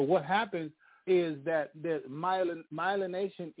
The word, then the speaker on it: Myelination: But the point is, that's all to come back to this, that it's called myelination. Myelination. I believe it's spelled M-Y-L-E-N-A-T-I-O-N.